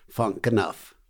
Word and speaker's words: Funk Knuff: The band is called Funk Knuff, and it's spelled F-U-N-K and then K-N-U-F, Funk Knuff.